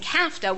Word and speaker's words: CAFTA: But because we are facing CAFTA, which rhymes with NAFTA,